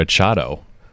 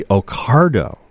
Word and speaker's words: Ocado: I would say Ochado. – That's a good guess, but it's actually Ocardo. It's like a C-A-R sound, Ocardo.